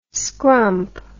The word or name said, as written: scrump